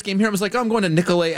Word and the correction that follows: Nicolet: Avenue. And they're like, you idiot. It's Nicolet.